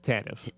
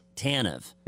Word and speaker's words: Tanev: Now the number 13 for the Kraken, is it Tanev? Is that how you pronounce it? – Tanev. – Tanev.